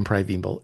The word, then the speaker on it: Primeval: My watching is American Primeval, and I know it's not spelled as you might think it is, but I looked it up like six different ways, and that's how you pronounce it, American Primeval.